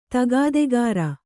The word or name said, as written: ತಗಾದೆಗಾರ